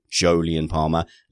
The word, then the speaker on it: Jolyon Palmer: Firstly, a very, very prickly interview with Julian Palmer. Sorry, Jolyon Palmer. Look, people get upset when I say that wrong. But if you have an uncommon name that sounds very much like a common name, you can't get all that upset.